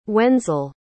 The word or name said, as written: wenzel